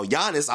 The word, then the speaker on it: Giannis: The G and Giannis is silent.